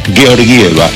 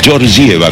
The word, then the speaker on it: Georgieva: El origen es búlgaro y es Georgieva, pero en Estados Unidos, que todos lo localizan, le dicen Georgieva, como si fuera, sí, George.